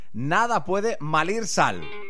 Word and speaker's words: Nada Puede Malir Sal: Se llaman Mugre y acaban de publicar su nuevo álbum, Nada Puede Salir Sal. No, lo he dicho mal yo. Es Nada Puede Malir Sal.